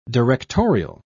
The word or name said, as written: directorial